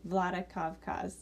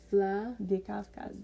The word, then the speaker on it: Vladikavkaz: How do you say it again? – Vladikavkaz. – Vladikavkaz? – Yeah.